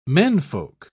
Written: menfolk

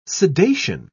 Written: sedation

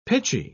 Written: pitchy

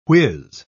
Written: whiz

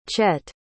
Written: chet

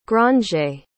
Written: grangere

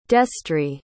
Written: destrie

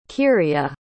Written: Kirya

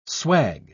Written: swag

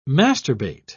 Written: masturbate

